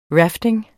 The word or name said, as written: rafting